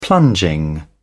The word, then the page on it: plunging: British English